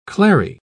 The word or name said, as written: clary